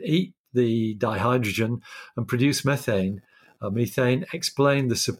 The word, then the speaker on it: methane: But what wasn't really commented on at the time was the high fraction of that, that set of different molecules that was actually methane, or methane as it's sometimes pronounced.